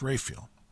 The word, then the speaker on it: Raphael: Looks like Raphael, but it's pronounced Raphael.